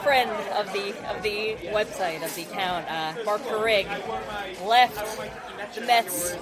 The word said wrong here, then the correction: Craig: You're mispronouncing Craig. – Craig, sorry. I apologize.